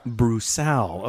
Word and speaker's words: Broussal: Broussal.